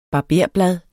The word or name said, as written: barberblad